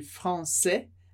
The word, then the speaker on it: français: No caso de français, a palavra termina em S, e tipicamente em francês, a gente não pronuncia o S no final da palavra. Então, français, masculino, francês, français.